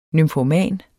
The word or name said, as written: nymfoman